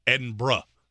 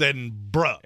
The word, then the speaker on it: Edinburgh: Edinburgh. It's Edinburgh. Edinburgh. There is no borough, there is no burg, there is no none of that. It's Edinburgh.